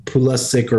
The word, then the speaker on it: Pulisic: They're saying they're Pulisic or I don't know. – What yeah, I didn't. Saying, I think it's a hard C rather than a ch on the end.